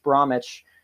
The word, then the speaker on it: Bromwich: was held in a West Bromwich, also another silent W,